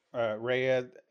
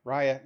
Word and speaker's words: Raya: Is it Raya or Raya? – Raya. It's spelled R-A-Y-A. I keep saying Raya. It's Raya the Last Dragon.